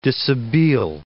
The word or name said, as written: dishabille